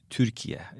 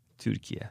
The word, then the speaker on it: Türkiye: Yes, it's everything is the same in our language, but U after T, it's U sound. – Like a German U. – Yeah, U sound, which is Türkiye.